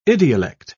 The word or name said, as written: idiolect